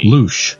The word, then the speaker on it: louche: Louche. – Also, it is pronounced louche. – Louche. – Louche. – Louche. – Yes. Louche. – Louche. Louche. – Louche. It's exactly like douche then.